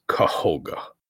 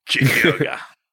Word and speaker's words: Kohga: Spelled differently, though. That Koga is spelled K-O-G-A. This one is spelled K-O-H-G-A. So he is Kahoga. – Gotcha. – Not really. – Kahoga.